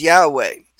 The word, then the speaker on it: Yahweh: That is, the letters Yod Heh Vav Heh, most commonly pronounced as Yahweh.